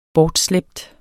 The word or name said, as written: bortslæbt